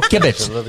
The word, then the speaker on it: kibitz: I'll kibitz. – Oh, kibitz. Kibitz. – Oh, he had the accent on the wrong syllable.